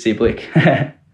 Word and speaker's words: Cibilic: I remember he changed the last name of Cibilic to Cibilic. He put a H on the end so people would pronounce it correctly. But he took it off because he wanted to keep it the same, and nobody's Croatian here, so they're not going to be pronouncing Cibilic. So it's just Cibilic.